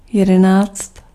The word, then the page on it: onze: France; [ɔ̃z]